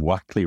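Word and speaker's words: Wakley: pointed out to me that they pronounce him Wackley rather than Wakely, because I had pronounced him Wakely up until then.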